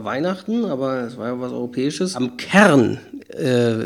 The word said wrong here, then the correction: CERN: CERN, eigentlich spricht man es meistens okay, CERN.